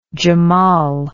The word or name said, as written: jamal